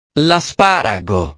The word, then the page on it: l'asparago: italiano